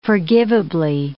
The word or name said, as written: forgivably